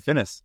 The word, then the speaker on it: Finnäs: Finnäs.